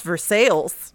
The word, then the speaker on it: Versailles: Jane, I regret to inform you, as someone living in Missouri, that we have a town whose name is spelled like Versailles, but that's not how you say it. It's pronounced Versailles.